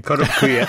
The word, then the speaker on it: Kotobukiya: Kotobukiya. – Kotobukiya.